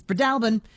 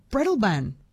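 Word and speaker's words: Breadalbane: Katrina in Roslyn says, Fiona's correct. It's Bredalbin. Louise in Nowra says, Jess, I've only ever heard it as Bredalban. Oh, I'm probably mispronouncing the mispronunciation. She said she learnt it from a hotelier in Yass.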